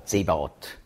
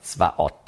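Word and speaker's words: Zvaot (צבאות): Oder Zebaot. Hebräisch spricht man aus Zvaot.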